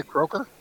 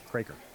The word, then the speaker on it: Craker: And is it a Croker? – Craker. That's how you pronounce it.